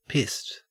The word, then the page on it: pissed: Australian English